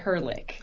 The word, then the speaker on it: Ehrlich: Well, the way you pronounce it was closer to like the actual German pronunciation. – Yes. – But we go by Ehrlich.